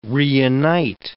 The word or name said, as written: reunite